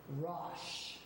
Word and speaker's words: Rosh: How do you say chief in Hebrew? The Hebrew consonants. Resh, Aleph, Shin, Rosh. R-O-S-H. Now, you're going to probably say, well, that sounds a lot like Russia.